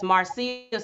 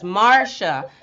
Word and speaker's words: Marcia: Because I was saying that her name is Marcia, Steve Harvey's first wife. I was saying that her name is Marcia, but really her name is Marcia. So it's spelled M-A-R-C-I-A.